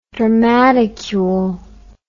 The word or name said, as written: dramaticule